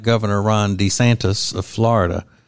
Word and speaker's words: DeSantis: How you pronounce it, DeSantis.